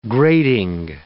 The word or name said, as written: grating